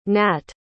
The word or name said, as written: nat